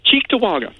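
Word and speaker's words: Cheektowaga: Chickatawaga.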